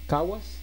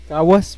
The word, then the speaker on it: Caguas: Caguas. – Caguas.